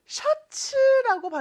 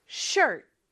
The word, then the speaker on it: shirt: 아, 그리고 참고로 셔츠라고 발음하면 안 되고 shirt, shirt 이렇게 발음해 주세요.